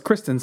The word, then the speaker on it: Kristen: Moving on from the Kirsten. I can't even say her name. – Is it Kirsten? No, it's Kristen Stewart. – I think so. – Kristen. Yeah, it is Kristen.